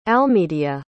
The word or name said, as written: almedia